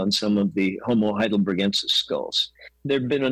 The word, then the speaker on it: Heidelbergensis: Why does Homo heidelbergensis, or gensis, or gensis? How do you pronounce it? – Hard G. – Okay. – Heidelbergensis.